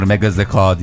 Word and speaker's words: Mega Zeka: Türkçe o şey diye okunur, Mega Zeka diye okunur. – Mega Zeka doğru.